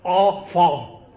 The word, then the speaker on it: enfant: Und bloß, dass die Franzosen das N eben nicht so aussprechen wie wir eben Anfang, sondern sagen Enfant.